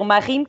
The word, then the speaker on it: Marine: mas ao registar-me no, no, no, civil, enfim, não conseguiu pronunciar bem o meu nome e as senhoras perceberam Marine, que é muito comum em França, e assim ficou.